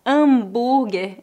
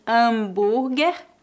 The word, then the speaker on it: hambúrguer: Cheeseburger ou hambúrguer. Em português, a letra H é muda, por isso dizemos hambúrguer.